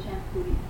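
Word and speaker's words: Champoeg: I'm sure you're all very familiar with Shampui. – Okay. Is that the way it's pronounced? – That's good.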